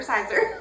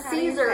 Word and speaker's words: Caesar: Don't tell Caesar that, or Sizer. – Caesar. We don't even know it. Caesar is the correct pronunciation, but everybody calls it.